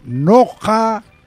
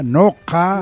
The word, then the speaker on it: nocka: En quichua, noja. N-O-C-K-A, noja. – Noja. Sonido cultural, maestro. – Claro. – Dígale eso. – Cultural, noja. Cultural, cultural. – No, cultural. – Noja, pusieron noja.